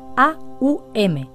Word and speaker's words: om: Se trata de una sílaba pronunciada como A-U-M, que aparece a menudo en los cantos devocionales o kirtan.